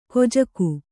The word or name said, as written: ಖಜಾಕೆ